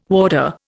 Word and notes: warder: Indian

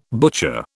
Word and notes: butcher: British English

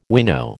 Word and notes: winnow: American English

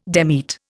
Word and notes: demit: Indian

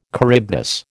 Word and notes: Charybdis: American English

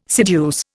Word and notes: seduce: Indian

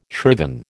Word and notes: shriven: American English

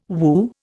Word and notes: Wu: Indian

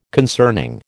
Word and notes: concerning: American English

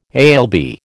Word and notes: alb: American English

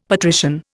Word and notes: patrician: Indian

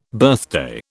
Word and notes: birthday: British English

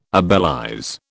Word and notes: obelize: British English

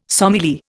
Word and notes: sommelier: Indian

